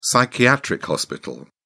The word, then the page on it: psychiatric hospital: British English